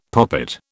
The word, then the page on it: poppet: British English